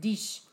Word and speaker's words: dix: Dix? – Right? I think it's that. – Dix. – It's spelled D-I-X, but it's dish.